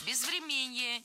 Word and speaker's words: безвременье: Но еще и инфраструктура, пострадавшая от бездеятельности или же там безвременье еще украинского прошлого. – Безвременье, неверно, правильно, безвременье. Ударение в этом слове падает на корень врем. Время, безвременье.